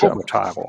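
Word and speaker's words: Ciobotaru: Jetzt hoffe ich, dass ich den Namen richtig ausspreche. Ciobotaro. – Ist das richtig? Ciobotaro, glaube ich. – Ciobotaro, ja. Okay.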